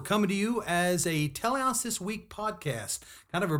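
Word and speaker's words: teleios: And just real quick, we say Telehouse.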